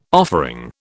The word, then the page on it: offering: British English